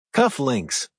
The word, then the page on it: cuff links: American English